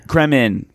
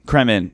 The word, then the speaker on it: Kremen: Kremen. Kremen. – C-R-E-M-E-N. – K-R-E-M. Like semen, but K-R before it. – Gotcha. – K-R-E-M-E-N.